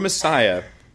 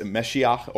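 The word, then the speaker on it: Mashiach: The word Messiah is actually a word meaning anointed, and that's a Hebrew word. It's a Meshiach or Messiah, as like an English pronunciation of it is.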